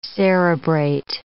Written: cerebrate

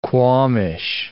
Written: qualmish

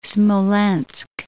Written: Smolensk

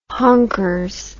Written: honkers

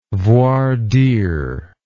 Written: voir dire

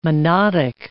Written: monodic

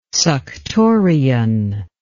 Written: suctorian

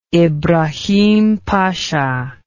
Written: ibrahim pasha

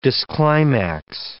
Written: disclimax

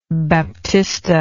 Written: baptista

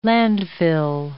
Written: landfill

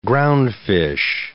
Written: groundfish

